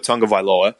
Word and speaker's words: Tagovailoa: Tua Tagovailoa.